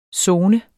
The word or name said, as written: zone